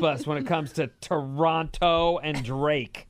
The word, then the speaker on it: Toronto: Stop saying Toronto. Use a soft T at the end. Toronto? – No, you're supposed to say it like this.